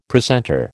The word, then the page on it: precentor: American English